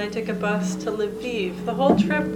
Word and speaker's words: Lviv: If you say it in Russian, Lviv is Lvov. Like the I turns into O in Russian, so Lviv is the Ukrainian pronunciation.